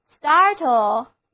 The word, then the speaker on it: startle: 有一个单词同样表示使人吃一惊的意思，就是 startle， S-T-A-R-T-L-E， startle，字母组合 A-R 发作 R 的长音，单词就读作 startle。